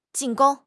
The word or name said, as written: 进攻